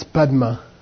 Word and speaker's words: Padma: And that's Padma. That's the Tibetan way of squishing the two syllables together, Padma. Like when you say Om Mani Padme, it's a corruption of Padma, Padme. Okay.